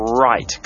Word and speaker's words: right: right คือขวา R I G H T ออกเสียงว่า right เสียงตอนท้ายนะครับไม่ใช่ like ครับ